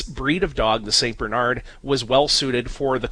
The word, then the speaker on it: Bernard: Well, I got into the habit of saying Bernard, putting the accent on the first syllable, because if you say Bernard, so many people immediately think of the dog rather than the church history behind it.